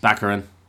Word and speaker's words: Baccarin: Monica Baccarin, how do you say her name? – Baccarin.